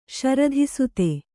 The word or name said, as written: ಶರಧಿಸುತೆ